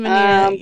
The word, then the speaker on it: Mannina: Christine Manina.